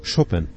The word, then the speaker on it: Chopin: Das Haus von Mikołaj und Justyna Chopin, oder Chopin, die Aussprache in Polnisch klingt eigentlich so.